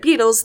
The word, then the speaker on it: Beatles: It's spelled B-E-A-T-A-L-S, but Beatles, like beat on a drum, is a tribute to Buddy Holly and the Crickets, which I didn't realize that.